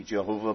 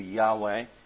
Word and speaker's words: Yahweh: There's absolutely no certainty about how this name should be pronounced, but the view today is that it shouldn't be Jehovah, but it should be Yahweh.